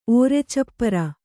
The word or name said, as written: ಓರೆಚಪ್ಪರ